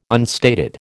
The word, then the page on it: unstated: American English